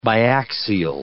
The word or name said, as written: biaxial